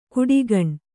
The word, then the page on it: ಕುಡಿಗಣ್: kuḍigaṇ